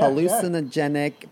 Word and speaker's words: hallucinogenic: Because mugworts, well, people say that it has a hallucinogenic properties. Yeah. Am I saying that right? Yeah, hallucinogenic properties. – You got it. Good job.